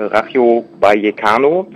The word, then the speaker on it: Rayo Vallecano: Alfon ist ein, äh, wie gesagt, 22-jähriger Freund und Genosse, der in einem Fußballclub, äh, und zwar bei den Rachio ich entschuldige mein Spanisch, ich bin nicht so super in der Aussprache, äh, Rayo Vallecano, das ist der Fußballclub in der ersten Liga in Spanien.